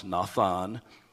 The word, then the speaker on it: nathan: That phrase, given you, is from the Hebrew word nathan. It's pronounced nathan, and it means assigned or set.